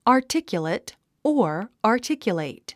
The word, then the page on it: articulate: ɑrtíkjəleit; アーティキュレイト